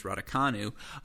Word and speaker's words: Raducanu: And yes, folks, I finally learned how to pronounce the name. It's Raducanu.